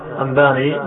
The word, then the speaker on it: أنباني: قال المصنف أو قال الناظم رحمه الله تعالى: مسلسل قل ما على وصف أتى مثل أما والله أنباني الفتى، أنباني مخففة ليست مهموزة، مهموزة كتابة لكن بالنطق مخففة، مثل أما والله أنباني الفتى كذلك قد حدثني قائمة أو بعد أن حدثني تبسما.